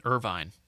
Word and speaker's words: Irvine: The actual city Buckland is in Irvine. – Irvine. – Or Irvine. It's Irvine. So it's Irvine or Irvin, however you want to pronounce it. I would say Irvine, but it's whatever.